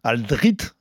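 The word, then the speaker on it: Alldritt: Aldrit, si je le dis bien.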